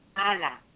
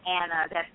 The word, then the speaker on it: aina: The Hindi word is ana, which is actually spelled A-I-N-A, but you don't pronounce the A-I the way we would. So you almost pronounce that like Anna, but it's going to be a higher Anna. So ana is how you pronounce it.